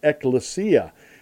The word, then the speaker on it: ekklesia: If you look at the Greek word in the New Testament for church, it's called ekklesia, or it's pronounced ekklesia.